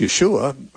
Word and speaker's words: Yeshua: Even the words of Jesus which were probably spoken in Aramaic, which is very close to Hebrew, and his name was probably pronounced Yeshua by his friends and family.